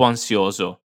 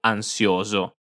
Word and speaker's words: ansioso: Ero troppo ansioso. Ero troppo ansioso. – E attenzione alla pronuncia di ansioso. Cerchiamo di pronunciare ogni singola vocale, mi raccomando.